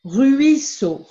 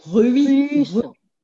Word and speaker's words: ruisseau: Yes, ruisseau. You must pronounce first the U, which means U.